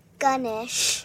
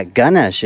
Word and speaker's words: Ganesh: Ganesh. – Yeah, Ganesh is the Hindu god of knowledge and wisdom, which is, so you just add a G in front of my name, and it's Ganesh.